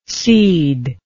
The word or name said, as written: ceder